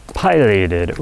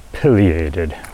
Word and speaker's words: pileated: Now, I often get asked, how do you pronounce it? What's the proper pronunciation? Pileated, pileated. I have done research and it can go either way. Yes. However you want to pronounce it. Tomato, tomato. Yeah, however you want to is correct.